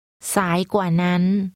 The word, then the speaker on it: สายกว่านั้น: สายกว่านั้นสายกว่านั้น This is pronounced with a rising tone, a low tone and a high tone.